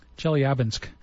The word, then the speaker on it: Chelyabinsk: Chelyabinsk.